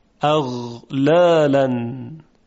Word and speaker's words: اغلالن: اغلالن، اغلالن. غین در اینجا ساکن هست، به بهترین وجه میشه اون رو تلفظ کرد. اغلالن. باید دقت کنیم که مثل قاف نشه.